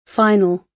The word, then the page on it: final: British English